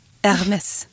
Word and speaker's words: Hermès: Hermès. – Hermès.